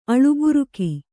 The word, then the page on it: ಅಳುಬುರುಕ: aḷuburuka